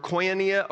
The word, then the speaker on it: koinonia: So the Greek word for this partnership, for the word partnership, is the word koinonia or koinonia. K-O-I-N-O-N-I-A.